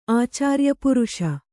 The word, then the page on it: ಆಚಾರ್ಯಪುರುಷ: ācāryapuruṣa